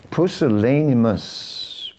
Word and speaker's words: pusillanimous: Pusillanimus. Never heard of that word. Oh, pusillanimous. Pusillanimous. That's how it's pronounced.